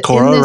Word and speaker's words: Cora: And Cora rhymes with Laura.